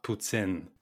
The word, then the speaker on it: poutine: It's pronounced poutine.